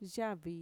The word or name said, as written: yavi